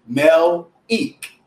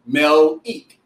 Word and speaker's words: Melik: So my name is actually Melik. Roland says Malik. So everyone knows me as Malik. Whenever I'm out, they always call me Malik, but it's actually Melik. Melik. But yeah, Melik, just simply M-E-L-I-K, Melik.